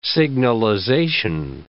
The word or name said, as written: signalization